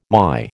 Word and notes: MY: American English

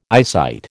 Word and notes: eyesight: American English

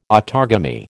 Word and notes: autogamy: American English

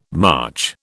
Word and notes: March: British English

